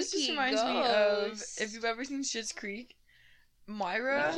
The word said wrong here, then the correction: Moira: But okay, Moira.